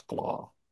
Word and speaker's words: claw: Bass, for the Billy the Bass and the craw. – It's not craw, it's claw.